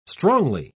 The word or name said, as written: strongly